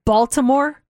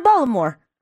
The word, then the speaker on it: Baltimore: She goes, welcome to Baltimore. I'm like, who's Baltimore? She goes, you don't pronounce a T here. I'm like, wait. So you're telling me we've been saying Baltimore when it's really Baltimore.